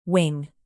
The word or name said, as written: wing